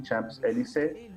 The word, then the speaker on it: Champs-Élysées: Champs-Élysées, desde la, triunfo de la Segunda Guerra Mundial.